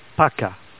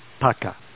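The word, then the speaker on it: paka (пока): And you could just say bye, and bye is pronounced paka, paka.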